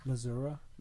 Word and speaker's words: Missouri: I heard you, and that's why I'm correcting you by telling you it's St. Louis, you know, Missouri, not Mississippi. – It's pronounced Missouri. – Missouri, yeah.